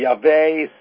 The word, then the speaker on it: Yahweh: And it should be Yahweh, start like a Y sound, but we'll go ahead and use the English terms instead of the more correct pronunciation.